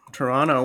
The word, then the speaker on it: Toronto: That's right. Toronto. Well pronounced.